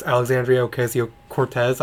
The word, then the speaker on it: Alexandria Ocasio-Cortez: And I'm an absolute stan for Miss Alexandria Ocasio-Cortez. I'm pretty sure I said her name right. – I think you did.